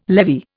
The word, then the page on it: levy: Indian